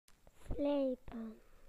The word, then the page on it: to sharpen: British English